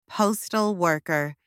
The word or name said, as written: postal worker